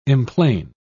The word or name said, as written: emplane